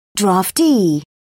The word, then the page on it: draftee: British English